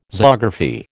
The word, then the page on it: zoography: American English